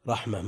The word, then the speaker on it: رحمة: رحمة مثلا تكتب بالتاء رحمة، لكن هل يمكن أن يوقف عليها رحمة؟ إن رحمة الله قريب، تكتب بصورة التاء وتنطق هاء. وهنا هل يمكن أن يقال لا حين وإن كتبت بالتاء مثل رحمة؟